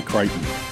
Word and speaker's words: Creighton: I'm going to go with NC State because I don't like the way they pronounce Creighton. It should be Creighton.